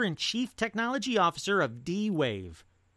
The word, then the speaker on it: D-Wave: It's called the Dwave Quantum 2, and I didn't say Dave, and I didn't mispronounce Dave. It is D-W-A-V-E Quantum 2.